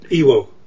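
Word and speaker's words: Iwao: And I would uh, credit uh, Ewow. I never pronounce it right. Ewo.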